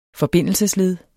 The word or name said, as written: forbindelsesled